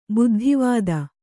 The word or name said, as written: ಬುದ್ಧಿವತಿ